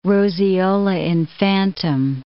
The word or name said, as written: roseola infantum